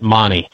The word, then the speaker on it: Magni: Yeah, the brand of gyroplane that I have flown several different brands, but this one is known as Magni, M-A-G-N-I, and the kit comes from Italy. They pronounce it Mani, but they have several different models, and all the guys that have built them over the years, we meet once a year.